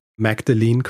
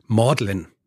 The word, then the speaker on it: Magdalene: Dann das College, das er in Cambridge besucht hat, heißt nicht Magdalene College, so wie ich es ausgesprochen habe, sondern Maudlin. Mhm. Magdalen wird es ausgesprochen.